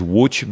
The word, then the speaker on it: Łódź: L-O-D-Z. – Oh, L-O-D-Z. – And that's pronounced what? And it's pronounced in my language is Łódź because over L you have a little slash.